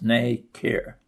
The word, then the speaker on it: nacre: The smooth layers of minerals and protein that the mollusk deposits on the foreign particle are called nacre, pronounced nay-care, N-A-Y-K-E-R.